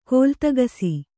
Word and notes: ಕಾಲ್ತೆಗಿಸು: kāltegisu

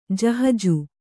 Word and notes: ಜಹಜು: kannada

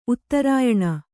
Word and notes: ಉತ್ತರಾಯಣ: uttarāyaṇa

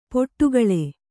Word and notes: ಪಡುಕುಳಿ: paḍukuḷi

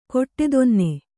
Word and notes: ಕೊಟ್ಟೆದೊನ್ನೆ: koṭṭedonne

